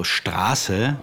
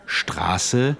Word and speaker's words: Straße: Yeah, the scharfes S also indicates that the preceding vowel is long. For example, Straße, which is street, or Maß, which is measure, versus Masse. Masse is amount. It's spelled M-A-S-S-E. And that's a short vowel sound. So, Straße, Masse. – Straße, Masse. Mm-hmm.